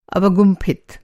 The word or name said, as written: अवगुंफित